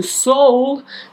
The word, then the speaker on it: Seoul: And now let us go to Seoul. That is how you pronounce that city's name, as I have googled it to make sure, and to South Korea and speak about the Raincoat killer.